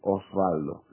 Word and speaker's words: Osvaldo: Porque es con W y Oswald pronuncian. Yo decía, no, Oswald no, es otro tipo de nombre. Yo soy Osvaldo. Y no hay forma. Me decían Waldo, me decían Oswald, que no es mi nombre.